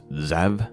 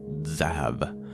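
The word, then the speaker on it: Tzav: It's kind of hard to pronounce. Zav, I believe, would be the proper pronunciation.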